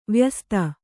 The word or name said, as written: ವಯಃಸ್ಥ